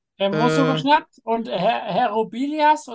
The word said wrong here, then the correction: Rubiales: oder Robias? – Rubial, oder Rubiales.